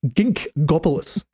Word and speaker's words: Ginkgopolis: Got the reprint of Ginkopolis, or Ginkgopolis. I need to pronounce that right. – I butcher it too. Gink. – Ginkgopolis, yeah.